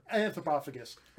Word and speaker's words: Anthropophagus: Anthropophagus.